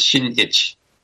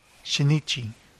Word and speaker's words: Shinichi: But like a name like Shinichi, that last I you don't pronounce, and then there's a pause between Shin and Ichi, so it's Shinichi. – Shinichi.